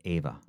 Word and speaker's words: Eva: But I think that was E-V-A. – No, that's Eva. Her name was Ava. – Yeah, Ava. – But I think she pronounced it Ava because she was German. – Yeah, and these names. – Ava Braun was E-V-A-B-R-A-U-N. – Yeah, and how do you pronounce A-V-A? – Ava. – Yeah, Ava. – But her name was spelled Eva. – Doesn't matter. Her name is not Eva Braun, it's Ava Braun.